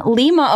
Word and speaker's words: Lima: Next up, we have Robin, correction, Lima, Ohio. Oh, shit. Oh, it's pronounced with a long I, as in lima bean, not as in Lima, Peru.